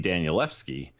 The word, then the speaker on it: Danielewski: And thank you for not pointing out that I said his name wrong. It's going to be hard after saying it for years and years. Mark Z. Danielewski screenplays.